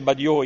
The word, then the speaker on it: Batlló: Allora prendete per esempio la famosissima casa Badiò, si scrive Batlló, ma si dice Badiò in catalano, no?